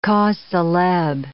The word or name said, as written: causes celebres